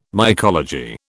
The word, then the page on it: mycology: British English